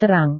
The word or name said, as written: terang